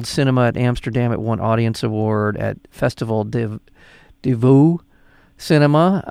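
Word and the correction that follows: Festival du Nouveau Cinéma: I didn't even say that right. Nouveau, I'm sorry. – Yeah. – Festival du Nouveau Cinema, it won the Audience Award, People's Choice Award, et cetera, et cetera.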